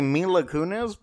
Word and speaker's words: Mila Kunis: I was going to say Mila Kunis, but I thought, am I pronouncing that right? – You did. – I think I did. You got it. You nailed it.